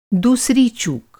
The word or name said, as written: चूक